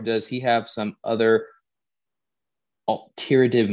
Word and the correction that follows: ulterior: motive? Am I saying that right? – Ulterior? – Yeah. – You said ulterior. Alteriative? Alteriative? – Ulterior. Ulterior, sorry. Sorry, I'm this many years old.